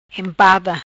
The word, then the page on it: Empada: Patua